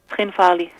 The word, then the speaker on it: Tskhinvali: Tskhinvali.